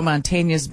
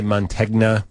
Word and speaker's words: Mantegna: A lot of people say Mantegna. – Yeah. – Who says wrong? You know, all you have to think of is who says lasagna. It's lasagna.